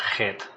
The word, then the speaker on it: Heth: In the Hebrew, it's sort of pronounced like het, because you have the he here.